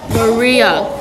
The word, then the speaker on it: Maria: You pronounce my name as Maria. It's a pretty hard name. Maria.